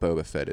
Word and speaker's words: Boba Fett: Mandalorians are what Boba Fett is. It's like a. – I thought he was a clone. – What did I say? Booba. Boba Fett. Boba. What am I not pronouncing here? Boba Fett.